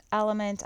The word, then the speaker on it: LMNT: You will also, if you follow me on Instagram, know I'm obsessed with Element. I've probably talked about it on here too. It's spelled LMNT, and it's a very clean ingredient-based electrolyte powder.